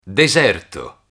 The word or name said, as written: deserto